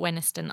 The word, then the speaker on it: Wenhaston: I think that's how you say it, Wenniston. I looked it up. It's spelt Wenhaston, but you wouldn't say it like that. Only if you were a non-East Anglian.